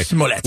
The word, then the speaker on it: Smollett: And then for Lake to say this is a Smollett deal. Am I pronouncing that right? – Yeah. – Okay. – Smollett.